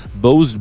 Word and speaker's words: Bows: Alors, peut-être que c'est pas la prononciation évidente. Bose, B-O-W-S, est une petite euh, douceur de type trip-hop des années fin 90, début 2000.